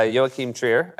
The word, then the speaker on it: Joachim Trier: Uh, um, so this is a director, uh, Joachim Trier.